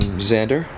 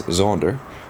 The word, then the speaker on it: Xander: Um, so my name is um, Xander. Um, in South Africa, I'm known as Zonder um, something I've had to come accustomed to here.